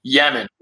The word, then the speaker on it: Yemen: Of all the deployments, when we talked about Iraq, Afghanistan, Beirut, Yemen, which was, or I should say Lemon. He always referred to it as the country that rhymes with lemon.